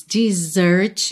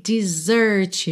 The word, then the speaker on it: desert: Seguindo, when it seems your friends desert you, atenção para a pronúncia dessa palavra, ok? Desert you, desert you.